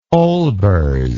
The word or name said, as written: olbers